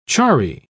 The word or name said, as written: charry